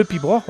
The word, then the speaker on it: piobaireachd: Alors, le pibroch, on va le voir, c'est vraiment classique, puisque ça s'écrit P-I-O-B-A-I-R-E-A-C-H-D, et ça se prononce tout simplement pibroch.